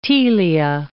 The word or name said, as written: telia